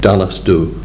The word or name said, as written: DALLAS DHU